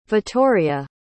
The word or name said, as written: vitoria